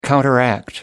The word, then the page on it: counteract: American English